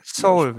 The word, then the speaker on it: Seoul: Der heißt Seoul. Ich weiß nicht, wie es ausgesprochen wird. Soul. Wie heißt es denn jetzt richtig? Ich weiß immer nicht. – Soul, würde ich sagen. Es ist so wie die koreanische Hauptstadt.